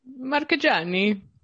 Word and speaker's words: Marcheggiani: Marchegiani.